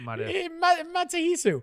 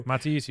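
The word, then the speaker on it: Matsuhisa: Then I went to Matsuyahu and then I went to, not Matsuyahu. how do you say it? – Matsuhisu. – Matsuhisu.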